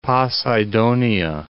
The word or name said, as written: Poseidonia